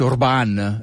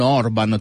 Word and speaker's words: Orbán: E in secondo luogo, perché si dice Orbán e non Orban, tutti mettono l'accento. Io per anni ho sbagliato.